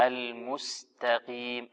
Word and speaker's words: المستقيم: هي القاف الآن مكسورة في أقل مراتب التفخيم فأعطيها مرتبتها. نعم. – اهدنا الصراط المستقيم. – المستقيم المستقي هكذا قي ق قو أق قي.